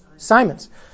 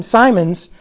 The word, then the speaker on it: Simons: And so for Simmons, or how do you pronounce it? – Simons. – So for him, Simons, his priority was on the humanity of Jesus, as he taught.